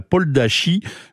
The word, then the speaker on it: Podlasie: qu'on dit la Poldachie, mais en fait ça s'écrit P-O-D-L-A-S-I-E.